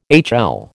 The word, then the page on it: HL: American English